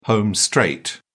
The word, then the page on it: home straight: British English